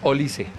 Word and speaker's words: Olise: La correcta pronunciación es Olise.